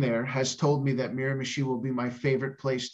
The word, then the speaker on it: Miramichi: And I am really excited to be in, how do you say, Miramichi? – Miramichi, yep.